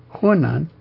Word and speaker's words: Hunan: Hunan, that's the one.